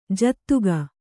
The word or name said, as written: ಜತಿಗೆ